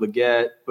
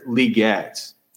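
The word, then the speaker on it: Legette: He told me that his name is pronounced not Leggett, but with the accent on the first syllable, Lee, Xavier Leggett.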